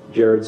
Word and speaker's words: Jared: Jared Scott Wilmore, J-A-R-E-D-S-C-O-T-T, and Wilmore, W-I-L-L-M-O-R-E.